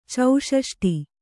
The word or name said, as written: ಚೌಷಷ್ಟಿ